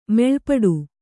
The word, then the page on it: ಮೆಲ್ಪಾಡು: kannada